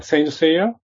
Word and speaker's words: Saint Seiya: Saint Seiya, is that correct pronunciation? – Saint Seiya.